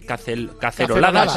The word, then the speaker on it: caceroladas: Nos ha mandado un oyente una aplicación, cuando estábamos hablando de los balcones, las caceloradas y demás, una aplicación para hacer caceloradas. ¿Cómo se dice? Caceroladas.